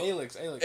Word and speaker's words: ALYX: I think a lot of people are incorporating the style of the LYAX.